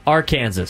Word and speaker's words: Arkansas: Say it right. – Arkansas. – Thank you.